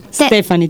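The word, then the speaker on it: Stephenie: Salendo alla quarta posizione troviamo Breaking Down di Meyer Steffi. – Stefani, ti correggo. – Stefani, correggiamo, okay, la pronuncia.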